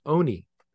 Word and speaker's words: Olney: And they pronounce it Oney. – Oney. – It's Oney. Oney. They don't say the L. We say Olney.